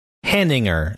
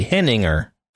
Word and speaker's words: Henninger: Henninger is how it should be. Henninger. – Mm-hmm. – Just eliminate the ER. Henning, right? Henning. – Mm-hmm. – Henninger. That's how it should be, but nobody pronounces it like that.